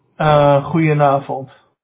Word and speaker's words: Goedenavond: Goedenavond.